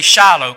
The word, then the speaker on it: Shiloh: Now it also says, nor a lawgiver from between his feet until, it's in Hebrew, I believe it's pronounced Shiloh. But we say Shiloh, kind of like the Battle of Shiloh down in Shiloh, Tennessee. That's the way we pronounce it.